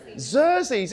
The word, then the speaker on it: Xerxes: Xerxes. It should be a Z. It's an X.